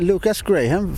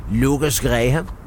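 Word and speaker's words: Lukas Graham: Ja, jag tänkte Lucas Graham från Danmark. Säger man inte så? Det var engelskt uttal. – Nej, jag ska ha dengelsk, dansk engelsk. Lucas Graham.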